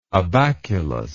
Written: abaculus

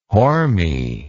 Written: horme

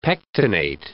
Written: pectinate

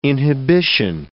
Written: inhibition

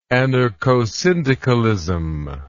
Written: anarchosyndicalism

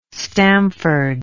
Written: stamford

